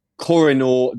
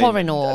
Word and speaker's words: Koh-i-Noor: Now, quickly, we mentioned it briefly, but forgive my pronunciation here. Talk to me about, is it the Koh-i-Noor Diamond? – Koh-i-Noor Diamond. – Koh-i-Noor.